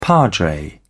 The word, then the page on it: padre: British English